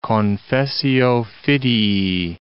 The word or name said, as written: confessio fidei